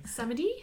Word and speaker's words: Samedi: Samedi.